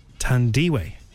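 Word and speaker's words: Thandiwe: You are pronouncing it right, but for 3 decades, she was calling herself Tandy. But it's probably just easier. – But now she's bringing her real name back, Tandiwe.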